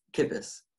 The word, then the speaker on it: Kippes: It's Kippes.